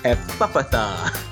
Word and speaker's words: Ephphatha: It's spelled E-P-H-P-H-A-T-H-A, which sounds like it should be Ephphatha. But, okay, so apparently it's Ephphatha. – Ephphatha. Okay. – As if it was like a double F.